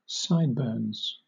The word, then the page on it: sideburns: British English